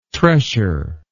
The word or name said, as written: tressure